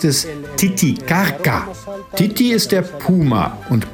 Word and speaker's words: Titicaca: Richtig ausgesprochen, Titicaca. Dieser heilige Ort der Inkas gab dem ganzen See seinen Namen. Die spanischen Eroberer konnten es aber nicht richtig aussprechen und so wurde aus Titicaca Titicaca. Aber korrekt müsste man sagen Titicaca.